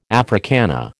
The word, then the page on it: Africana: American English